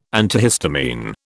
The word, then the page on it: antihistamine: British English